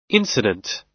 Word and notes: incident: American English

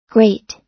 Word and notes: great: American English

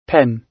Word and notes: pen: British English

